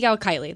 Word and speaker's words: Kylie: At Sea Kylie.